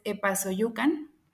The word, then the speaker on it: Epazoyucan: Maestro, sabemos que usted nació en, en un municipio de, Hidalgo, se llama, espero pronunciarlo bien, pero es Epazoyucan. – Es, es correcto.